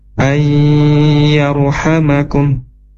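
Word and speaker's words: ay yarhamakum (أَنْ يَرْحَمَكُمْ): Asa rabbukum, asa rabbukum ay yarhamakum. Nah, di sini ada nun sukun ketemu dengan huruf ya, maka bacanya harus digunakan ya, dimasukkan ke hidung.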